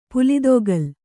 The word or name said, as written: ಪುಲಿದೊಗಲು